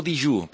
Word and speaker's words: dejú: o està en dejú. – Dejú o dijú? Perquè aquí... – Ara, molt bé, aquí ve l'altre. L'altre és que, en menorquí, aquesta E inicial de dejú la pronunciem I.